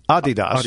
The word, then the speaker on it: Adidas: Yes, but the correct pronunciation, as we've learned every year when we're in Germany for IFA, is Adidas. – Adidas. – Because it's Adi and DOS, two brothers.